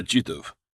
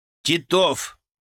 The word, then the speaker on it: Титов: «Проходи сюда, Титов». – «Титов!» — поправил самую большую шишку в интернате я. «Ударение на второй слог.